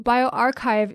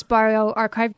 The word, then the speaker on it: bioRxiv: And so BioArchive is spelled B-I-O-R-X-I-V is BioArchive.